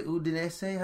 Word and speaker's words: Udinese: Udinese? How do you say that joint? – You said it right.